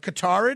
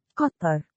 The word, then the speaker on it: Qatar: Like I probably was saying Qatar leading up to this, but Qatar it is.